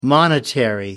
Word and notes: monetary: American English

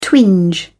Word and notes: twinge: British English

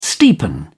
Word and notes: steepen: British English

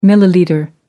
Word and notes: millilitre: American English

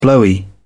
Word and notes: blowy: British English

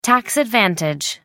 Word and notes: tax advantage: American English

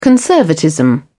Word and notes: conservatism: British English

